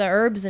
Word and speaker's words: herbs: Yeah, you're like into the herbs and shit, into the earth. – Is it herbs or herbs? – Herbs. – Herbs. – I don't think you pronounce the H.